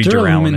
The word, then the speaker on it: Duralumin: I called it Durlumen, which is dumb. It should be Duralumin.